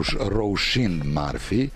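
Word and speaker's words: Róisín Murphy: Ona se zove Roushin Marfi i to sam čak proverio, irsko izgovaranje,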